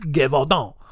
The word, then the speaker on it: Gévaudan: And the entire region of Gévaudan, I got to say it like that. Otherwise it's just going to sound Gévaudan. That's how I'm going to say it. No, it's Gévaudan.